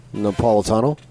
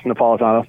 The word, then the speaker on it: Napolitano: Napolitano? – Yes, Napolitano, thank you.